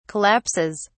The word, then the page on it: collapses: British English